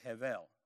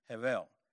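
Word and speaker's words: hevel (הֶבֶל): Vanity is the Hebrew word hevel. Okay, it's H-E-B-E-L, but it's spelled like a V, hevel.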